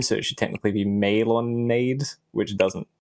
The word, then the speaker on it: Melonade: Um, and melon uh, how do I pronounce melonade? Because it has the accent, right? Okay. So, so it's just melonade.